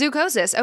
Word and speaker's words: zoochosis: Is that how you say it, zookosis? Yeah, it is zookosis.